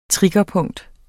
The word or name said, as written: triggerpunkt